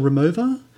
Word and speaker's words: Romuva: I think the pronunciation is Romuva. Is it Romuva? – Yes, that's correct. Yes.